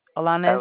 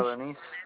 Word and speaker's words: Alaniz: Alaniz, A-L-A-N-I-Z. – And how do you pronounce your last name? Alaniz? – Alaniz.